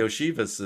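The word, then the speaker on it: Iosivas: It's Josephus. It's very, very weird based on how it's spelled to how it's said. So anyway, thank you. – Go ahead. – So yeah. Yoshivas is,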